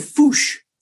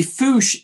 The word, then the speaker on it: fossa: It was raiding our kitchen when I was visiting a camp, and it's called a fouche, which is spelled F-O-S-S-A. And the fouche is a really ugly creature.